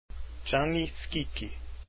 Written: gianni schicchi

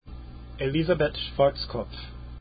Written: schwarzkopf

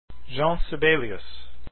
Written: Sibelius

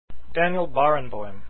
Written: barenboim

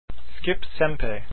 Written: sempe

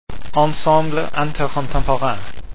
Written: intercontemporain

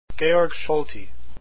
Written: Solti

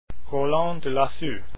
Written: delassus

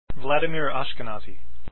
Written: ashkenazy

